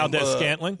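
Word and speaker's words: Valdes-Scantling: Valdez Scantling.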